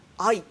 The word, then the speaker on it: 愛: バーチャル YouTuber、 VTuber の草分け、絆愛のプロデュースマネジメント事業を手掛ける絆愛株式会社、 AI じゃなくて愛って読むみたいですよ。